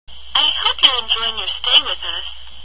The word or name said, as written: enjoying